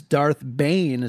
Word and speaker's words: Darth Bane: But Brad, there is one Sith Lord in particular that is credited for this rule of two back in the day, and I dare to venture to say his name. It is Darth Bane. Is that correct? – It is correct.